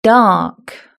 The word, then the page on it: dark: British English